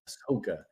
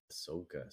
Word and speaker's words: Ahsoka: Ahsoka. Ahsoka.